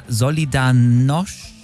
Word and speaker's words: Solidarność: der das russische Regime mit seiner Solidarność,